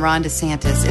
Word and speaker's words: DeSantis: Is it a short E or long E in the last name of the Republican gubernatorial candidate? Well, his wife says, everyone knows my husband Ron DeSantis is endorsed by President Trump.